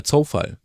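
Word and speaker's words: Coufal: Und tatsächlich, mein Preisträger ist dann Wladimir Zufall. Oder Zofall, wie er ja offenbar ausgesprochen werden möchte, aber es setzt sich nicht mehr durch. Ich habe neulich mit mehreren Kommentatoren drüber gesprochen und die haben gesagt, nee, wir nennen ihn jetzt Zufall.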